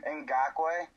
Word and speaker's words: Ngakoue: Ngakoue.